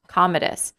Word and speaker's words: Commodus: Commodus. I've heard it pronounced many different ways, but Commodus is the one that I generally refer to. – I'll go with it.